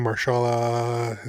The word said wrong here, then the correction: Mahershala: is that how you say his name? Marshala Ali, is that his name? – Mahershala.